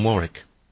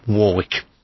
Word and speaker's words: Warwick: Now, of course, you would probably say the same as myself, you know, it's Dionne Warwick. – Yes. – Because it's Warwick. – Warwick. Yeah. – Yeah, because that is the way the Americans would pronounce it. And I was like, why? Because, we know Warwick Castle, don't we? Spelt the same way.